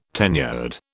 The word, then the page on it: tenured: British English